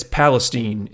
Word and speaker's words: Palestine: In it's spelled East Palestine, but it's Palestine is how they say it in Ohio.